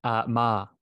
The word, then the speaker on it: Meagher: Good question. Ma, so it's pronounced the tricky one.